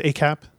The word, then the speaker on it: ACAP: What did this is, I think it's pronounced ACAP? – That's right.